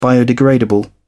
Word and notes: biodegradable: British English

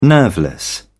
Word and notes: nerveless: British English

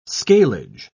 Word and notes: scalage: British English

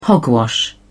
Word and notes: hogwash: British English

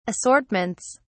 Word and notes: assortments: British English

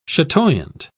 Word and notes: chatoyant: British English

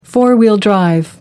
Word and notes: four-wheel drive: American English

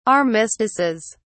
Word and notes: armistices: British English

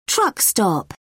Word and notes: truck stop: British English

